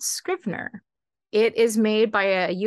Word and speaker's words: Scrivener: It's Scrivener, like scribe, like scriv.